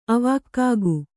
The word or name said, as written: ಆವಾಗ್ಗೆ